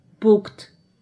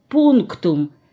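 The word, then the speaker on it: Punktum: Пукт. Я иду в сад. Пукт. Я добросовестно пишу везде немецкими буквами это непонятное пукт. Но когда диктовка кончается, то оказывается, что это слово произносится пунктум и означает точка.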